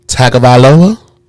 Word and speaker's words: Tagovailoa: How you say his name? – Tagovailoa.